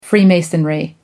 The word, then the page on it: freemasonry: American English